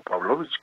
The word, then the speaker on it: Pavlovich: Y pues el hecho es que tomó protesta, rindió protesta Claudia Pavlovich, o Pavlovich,